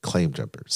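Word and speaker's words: Claim Jumper: I mean, there are Landry's restaurants all over, like the aquarium, as well as Cliff Jumpers. Did I say that right? – Claim Jumpers.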